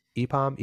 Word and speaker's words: EPAM: Is it pronounced EPAM Systems? – EPAM. – EPAM Systems, Etsy, Paycom, and PayPal.